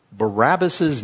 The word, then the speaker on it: Barabbas: We always pronounce Barabbas. Barabbas. Break it apart. Bar Abbas.